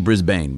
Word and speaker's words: Brisbane: Brisbane, it's spelled Brisbane, but it's Brisbane.